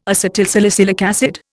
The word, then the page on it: acetylsalicylic acid: Indian